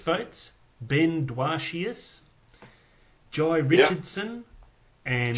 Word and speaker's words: Jhye: Jai, it's got like a H in it. – Yeah, J-H-Y-E. – Screaming, Jai! He's a fuckwit. – J-H-Y-E is how it's spelled.